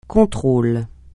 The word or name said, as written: contrôle